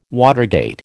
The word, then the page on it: watergate: American English